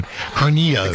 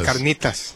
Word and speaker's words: carnitas: Did you put any hot sauce on the I'm sorry, I want to pronounce it authentically like you did. Carnitas. – Carnitas. – There you go.